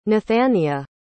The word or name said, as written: nathania